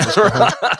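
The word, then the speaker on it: cajón: So that was Cajon with no E on the end, right? – Yeah, it was like Cajun, except for not pronounced like you're from Missouri. – Or ES. No ES on the end. No cojones, yeah, right? Yeah. Cajon.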